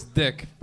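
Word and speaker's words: Dyck: In my defense, is spelled with a Y, not with an I. But where I'm from, where I'm born, they pronounce it dyke, but here they pronounce it dick. So I like to say I'd rather be a dick than a dyke.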